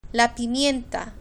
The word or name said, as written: la pimienta